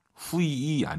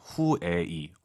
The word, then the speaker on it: 会议: 후이이 아니죠. UI 사이에 에 넣으셔야죠. 후에이. 워낙 빠르게 발음하니까 잘안 들릴 뿐이죠.